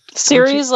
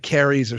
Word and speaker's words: Ceres: Hey, it's I think he called it like Carrie's or something like that. Oh, like, no, like he went from the soft C to the hard C.